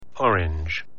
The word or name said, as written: orange